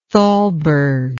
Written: Thalberg

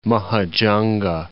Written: Mahajanga

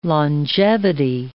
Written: longevity

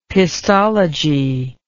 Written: pistology

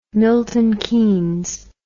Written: milton keynes

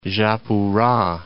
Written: Japura

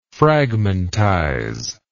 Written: fragmentizer

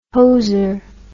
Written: hoser

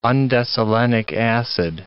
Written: undecylenic acid

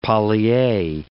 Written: poly(A)

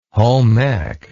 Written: home ec

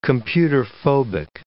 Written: computerphobic

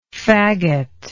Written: faggotty

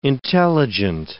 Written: intelligent

